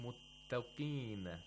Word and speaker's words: muttaqin: Inna lil muttaqin, muttaqin. The ta has tarqiq. If you say muttaqin, you pronounce the ta with tafkhim, that's incorrect.